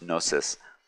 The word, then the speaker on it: gnosis: In a conversation Jen and I had, she brought it up and I was like, oh, you're right, it's actually pronounced gnosis.